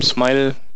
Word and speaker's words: SMIL: SMIL, Smile könnte man sagen. Ähm, ich glaube, Smile wird es auch ausgesprochen.